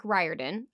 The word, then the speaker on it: Riordan: Based on the first book of the Percy Jackson and the Olympian series by Rick Riordan, I did look up the pronunciation of his name.